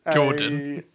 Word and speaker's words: Jordan: And a Gordon... is Jordan. I said Jordan. – Pardon? – Jordan, my friend. Jordan with a J, hard J.